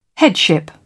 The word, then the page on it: headship: British English